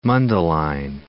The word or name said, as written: Mundelein